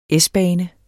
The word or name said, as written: S-bane